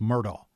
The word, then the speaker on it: Murdaugh: If you talk to those from that area, which I do, his name is pronounced Murdaugh.